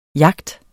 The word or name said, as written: jagt